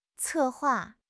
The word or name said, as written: 策划